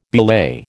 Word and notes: belay: American English

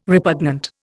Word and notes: repugnant: Indian

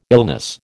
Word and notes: illness: American English

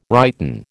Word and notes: righten: American English

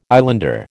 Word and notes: islander: American English

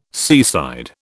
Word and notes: seaside: British English

